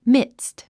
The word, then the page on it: midst: mídst; ミドゥスト